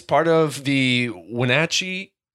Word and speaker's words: Wenatchee: I think it's a soft A. – Wenatchee. – Yeah, Wenatchee.